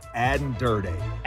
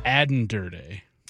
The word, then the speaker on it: Aden Durde: It's my pleasure to introduce Aden Durde. – Aden Durde.